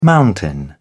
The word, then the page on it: mountain: British English